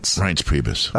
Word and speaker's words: Reince Priebus: How do you say his name? Is it Reince Priebus? – Reince Priebus.